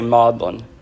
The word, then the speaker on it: Magdalen: By the way, that's spelled Magdalen, but English is weird. it's been around so long since that the pronunciation in normal English has changed, but they still say Magdalen.